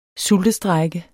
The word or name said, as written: sultestrejke